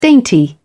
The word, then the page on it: dainty: British English